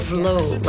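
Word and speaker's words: Tove Lo: It's like Tovlo, like love.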